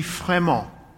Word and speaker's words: Frémont: Frémont. I'm pronouncing it with a French accent.